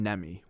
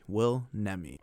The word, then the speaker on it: Will Nemi: Nemi. Will Nemi.